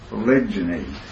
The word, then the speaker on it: polygyny: I guess you pronounce it polygyny, G-Y.